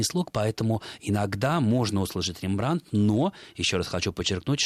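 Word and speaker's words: Rembrandt: Я никогда не слышал Рембрандта, хотя потом уже сообразил, что где-то когда-то читал о том, что такой вариант тоже возможен. В голландском языке, собственно, только ударение на первый слог – Рембрандт.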